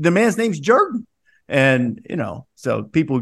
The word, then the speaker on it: Jordan: In that region, that little pocket of the South, the same name, which is spelled the same way as Michael Jordan, is pronounced Jordan.